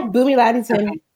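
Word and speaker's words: Bunmi Laditan: Am I saying your name correctly? – Yeah, Bhumi Laditan.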